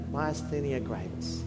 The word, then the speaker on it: Myasthenia gravis: Myasthenia gravis.